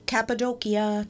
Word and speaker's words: Cappadocia: The world's largest underground city was recently discovered in Cappadocia, Turkey. – Oh, really? – Yes, and I know I'm saying that right because I've been there.